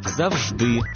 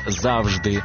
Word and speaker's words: завжди: Коли, приміром, в українському слові є подвійний наголос, вибирають спільний із російським «завжди», замість «завжди», «договір», замість «договір»,